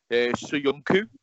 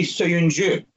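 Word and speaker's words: Söyüncü: It's actually Suyunju. – Suyunju. – Yeah, because he has those like dots above the U, which makes it like a U.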